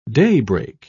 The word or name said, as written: daybreak